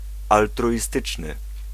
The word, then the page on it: altruiste: France; [al.tʁɥist]